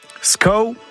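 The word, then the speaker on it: skav: SCAO, bon, ça s'écrit S-K-A-V, c'est comme ça.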